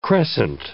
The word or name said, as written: crescent